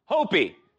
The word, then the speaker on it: Hopi: Hopi.